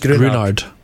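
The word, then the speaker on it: Gruinard: As I mentioned, Ian Rankin incorporated the Greenard. Is it Greenard? Is that how it's pronounced? – Wait, I always knew it as Grunard. Just Grunard. – Right, yeah. Sorry, it's Grunard. Apologies to the, what was it? – Chukters.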